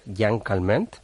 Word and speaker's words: Jeanne Calment: Jeanne Calment, como se pronuncia en el francés, vivió 122 años y 164 días.